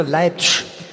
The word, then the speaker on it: Leipzig: Ich habe gehört, man sagt hier Leipzig. – Mensch, das wird nicht ausgesprochen, das Zig.